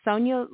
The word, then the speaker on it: Sonya: And my name is spelled S-O-N-Y-A, capital L-A. Well, it's all lowercase. It's Sonia Laray.